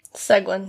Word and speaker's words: Seguin: Seguin, yeah.